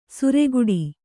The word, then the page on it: ಸುರೆಗುಡಿ: sureguḍi